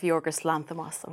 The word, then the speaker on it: Yorgos Lanthimos: I'm a big fan of Yorgos Lanthimos.